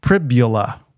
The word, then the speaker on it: Pribula: But Bo, by the way, the announcer in the stadium said his last name as Pribula. – Okay. – For Pribula. Whatever. Rhymes with fibula.